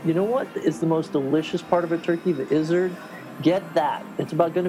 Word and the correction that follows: gizzard: I believe it's pronounced gizzard, but I get where you're going.